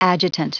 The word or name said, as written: adjutant